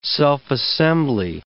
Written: self-assembly

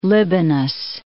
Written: Libanus

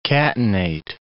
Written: catenate

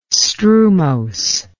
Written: strumose

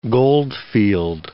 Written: goldfield

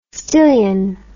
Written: stillion

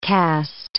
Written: cast

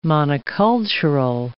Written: monocultural

